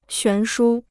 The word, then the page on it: 悬殊: xuán shū